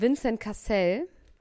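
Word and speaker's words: Vincent Cassel: Ich sehe hier gerade ein Hochzeitsfoto von Vincent Cassell.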